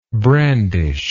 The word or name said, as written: brandisher